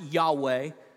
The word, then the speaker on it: Yahweh: That's the divine name of God, sometimes pronounced Yahweh. When you see capital L, then lowercase O-R-D, that's the translation of the word Adonai, which is the word for Lord or master or ruler.